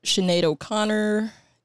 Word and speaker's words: Sinead O'Connor: Yeah, we got Sinead O'Connor.